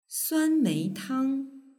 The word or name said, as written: suanmeitang